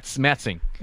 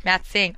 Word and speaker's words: Matsing: How do you say it? – Donacon. – No, it was the no. They were the Matsing. – Matsing.